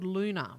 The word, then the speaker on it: Loona: She said she was recommended an app called Luna, which is spelled L-O-O-N-A.